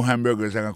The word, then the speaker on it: hamburger: N'a pas remarqué que dans le mot hamburgers, comme tu as dit, il y a trois syllabes là-dedans, quand tu appuies et puis plus ça, c'est sous premier syllabe là.